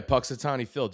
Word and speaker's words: Punxsutawney Phil: Am I saying that right? The Punxsutawney Phil? – Yeah, Punxsutawney Phil.